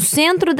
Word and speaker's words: centro: Un consejo que te va a ayudar a hablar más parecido a un brasileño es pronunciar como la letra U, todas las letras O al final de la palabra cuando no llevan la tilde. O sea, se escucha como no centro.